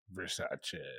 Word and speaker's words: Versace: Versace. – It's a soft C.